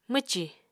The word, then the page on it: mwcì: Apatani